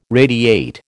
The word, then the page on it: radiate: American English